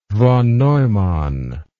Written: von Neumann

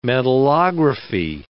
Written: metallography

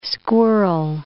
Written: squirrel

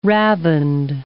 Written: ravined

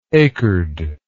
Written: acred

